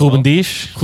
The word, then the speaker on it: Ruben Dias: Start, bench, sell Van Dijk, Ruben Dias. Did I pronounce that Ruben Dias.